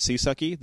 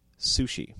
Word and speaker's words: CSUCI: Yes, named Sushi, which is how they want you to pronounce CSUCI. Rather than calling it Seasucky, they want you to pronounce it Sushi.